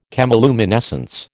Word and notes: chemiluminescence: American English

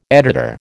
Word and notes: editor: American English

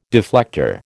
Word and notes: deflector: American English

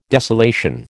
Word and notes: desolation: American English

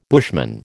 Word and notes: bushman: American English

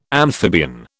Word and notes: amphibian: British English

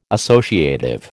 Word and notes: associative: American English

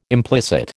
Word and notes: implicit: American English

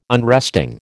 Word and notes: unresting: American English

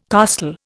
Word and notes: costal: Indian

